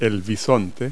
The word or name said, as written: bison